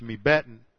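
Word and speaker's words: mibbeten: M-I-N plus beten, B-E-T-E-N, and when they're joined together, you lose the N, and it's just pronounced mebeten.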